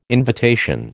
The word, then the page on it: invitation: American English